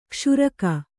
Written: ಕ್ಷೀರಕ

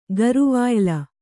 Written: ಗರುವಾಯ್ಲ

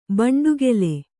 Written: ಬಂಡೆಕಲ್ಲು

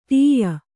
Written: ಠಾಯ

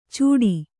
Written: ಚುಟಿ

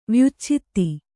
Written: ವ್ಯುಚ್ಛೇದ

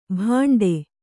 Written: ಭಾಂಡೆ

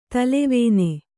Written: ತೆಲ್ಲವಣ್ಣ

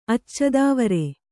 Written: ಅಚ್ಚದೊವರಿ

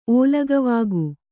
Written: ಓಲಗವಾಗು

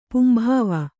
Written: ಪುಂಭಾವ

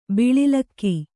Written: ಬಳಲ್ಕೆ

